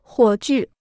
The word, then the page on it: 火炬: huǒ jù